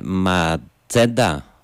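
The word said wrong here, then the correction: Macheda: δεν ξέρω πώς προφέρεται, Ματσέντα TV. – Μακέντα TV.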